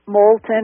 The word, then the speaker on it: Moulton: Well, one of the things, my father's last name is Moulton, so the English with a long O, Moulton, it's not French.